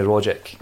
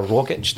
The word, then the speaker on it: Rogic: And Grace comes in to just remind us about Tommy Rogic, or Rogic, depending on how you want to pronounce that.